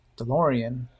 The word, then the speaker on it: DeLorean: DeLorean rhymes with Dorian, so I recommend playing the Dorian mode with blues songs that are more upbeat sounding, not completely sorrowful, but still blues.